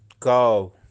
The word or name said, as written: kaav